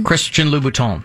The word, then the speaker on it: Christian Louboutin: Christian Louboutin.